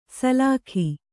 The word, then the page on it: ಸಲಾಕಿ: salāki